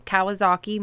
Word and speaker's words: Kawasaki: Kawasaki Manufacturing, which is where her husband Zeb also worked.